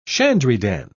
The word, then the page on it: shandrydan: British English